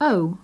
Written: oh

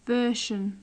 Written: version

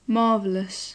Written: marvellous

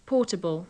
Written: portable

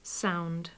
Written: sound

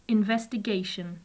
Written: investigation

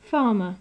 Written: farmer

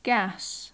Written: gas

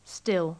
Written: still

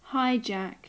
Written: hijack

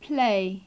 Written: play

